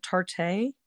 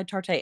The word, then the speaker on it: Tarte: And my mascara, I use Tartlet by, I think you pronounce it Tarte. – Okay. Yes. – Yes. Tartlet by Tarte.